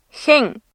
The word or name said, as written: хэн